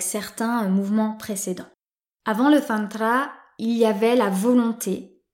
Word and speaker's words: Tantra: qui en sanskrit se prononce Tantra.